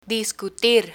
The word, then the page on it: discutir: spanish